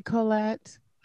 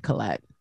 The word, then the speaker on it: Colette: It's supposed to be Colette. Oh, but, okay. You know, growing up, I didn't like it, you know, oh. So I just tell everybody to call me Colette.